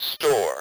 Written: store

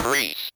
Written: priest

